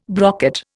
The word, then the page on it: brocket: Indian